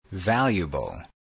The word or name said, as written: valuable